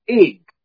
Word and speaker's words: Ygg: It's spelled YGG, the first three letters, but it's pronounced Ig for short.